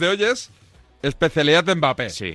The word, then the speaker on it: Mbappé: Y él me dijo que era una M, eh, claro, que es como es un sonido que en España no tenemos, que no es muda, la M es suave. Entonces, ¿cómo es? Sería algo así como Mbappé.